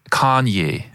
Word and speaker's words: Kanye: Kanye. – Kanye, finde ich, das sagst du super. – Ich glaube, die Betonung ist auf der ersten Silbe.